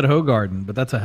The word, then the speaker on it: Hoegaarden: Somebody was like, it's Hoegaarden. Please pronounce it correctly.